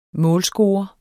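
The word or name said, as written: MÅLSCORERE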